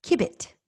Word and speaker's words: Cubitt: Cubitt? – Cubitt. – Spell it. – C-U-B-I-T-T.